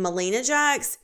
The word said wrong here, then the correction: Melania Jax: It is Melania Jax.